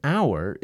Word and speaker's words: hour: The word hour is spelled with an H, but the H is not pronounced, so it's an hour.